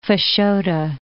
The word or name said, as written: Fashoda